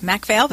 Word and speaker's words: MacPhail: MacPhail, but you're close.